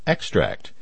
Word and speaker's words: Extract: Extract. As you can hear, extract in this case is pronounced with the stress on the first syllable,